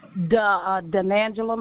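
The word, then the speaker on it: Dunangela: Denangela McKenzie, D-U-N-A-N-G-E-L-A, M-C-C-A-N-T-S.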